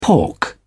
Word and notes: pork: British English

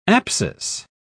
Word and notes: apsis: British English

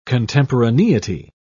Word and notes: contemporaneity: British English

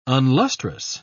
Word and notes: unlustrous: British English

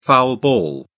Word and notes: foul ball: British English

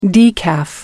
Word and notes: decaf: American English

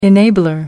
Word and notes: enabler: American English

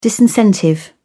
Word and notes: disincentive: British English

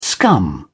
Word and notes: scum: British English

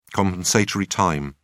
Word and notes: compensatory time: British English

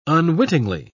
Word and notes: unwittingly: British English

